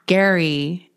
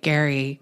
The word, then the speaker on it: Gerry: Gary, it's spelled Jerry though. So I actually had to write Gary. – Wait, how is it spelled? – G-E-R-R-Y. – Gary. – But that looks like Jerry.